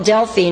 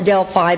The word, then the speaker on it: Delphi: But one day we went to a place called Delphi. Now, I've always called it Delphi, but the proper way to say it is Delphi.